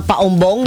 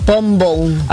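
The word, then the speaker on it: Paombong: Kaya pati yung paumbong, di ba sanay tayo? – Paumbong. Paumbong tayo. Eh, ano ba dapat? – Pombong.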